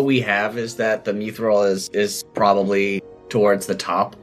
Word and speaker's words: Mithril: That is how it's pronounced. It's pronounced Mithril.